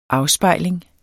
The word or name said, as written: afspejling